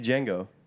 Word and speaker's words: Django: Be Django. – The D is silent.